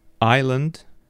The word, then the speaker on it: island: But the thing is, the word is pronounced aisle, and it's written as A-I-S-L-E. So remember, the two words, island and aisle, are pronounced without S. You can see the S written, but it's not pronounced.